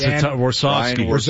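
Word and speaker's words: Warsofsky: Warchowski? What is it? – Yeah, it's Warsawski.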